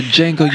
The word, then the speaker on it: Django: The Django, you black bastard. – The D is silent.